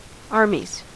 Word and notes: armies: American English

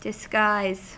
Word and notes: disguise: American English

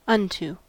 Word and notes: unto: American English

